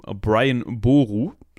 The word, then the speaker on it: Brian Boru: Äh, genauer gesagt handelt es sich hierbei um Brian Boru.